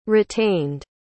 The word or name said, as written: retained